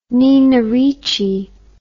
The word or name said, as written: Nina Ricci